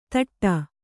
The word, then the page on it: ತೊಟ್ಟು: kannada